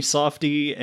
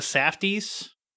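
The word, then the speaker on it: Safdie: They're generally pronounced as the Safties. But whatever. – Safty.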